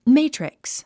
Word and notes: matrix: méitriks; メイトリックス

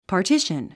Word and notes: partition: pɑː r tíʃən; パーティシュン

